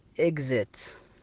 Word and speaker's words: exit: وأيضا يا أخوان يا أخوات، إذا أتى قبله وبعده حرفين علة، في أغلب الأحيان ينطق بحرف الزاء، مثل كلمات exam exam أو exit exit اللي هو المخرج.